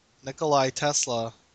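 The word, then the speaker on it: Nikola Tesla: Apparently, David Bowie played Nikolai Tesla.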